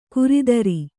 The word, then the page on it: ಕುರಿದರಿ: kuridari